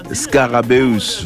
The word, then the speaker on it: Scarabeus: C'est ça se prononce comme ça. Scarabeus, voilà.